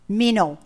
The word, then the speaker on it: meno: Meno, M-E-N-O, meno. The word abide means, or the word abide is meno in the Greek, and it means to continue in, to stay, to tarry, remain in a constant state of expectancy.